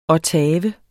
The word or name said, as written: ottave